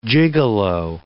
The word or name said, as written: gigolo